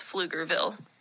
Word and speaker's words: Pflugerville: And here is the correct pronunciation of the five. So one is Green, two is Bernie, three is Nacogdoches, four is Waxahachie, and five is Pflugerville.